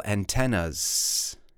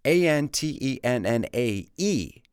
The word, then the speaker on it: antennae: Now, remember those other type of antenna, right, which is pronounced antenna in the plural. A N T E N N A E antenna.